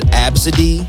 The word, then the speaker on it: Abcde: Somebody said the name A-B-C-D-E, but you pronounce it Absidy.